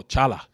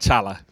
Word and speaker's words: challah: And it's called, when you read it, it's spelled C-H-A-L-L-A-H. First, you know, I'm like, oh, challah. You know, but it's not challah. It's challah.